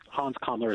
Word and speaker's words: Hans Kammler: But as you write in The Hidden Nazi, we have never heard the story of Hans Kammler. I hope I'm pronouncing his last name right. Is that right? – Yes, Hans Kammler is the guy. Exactly right.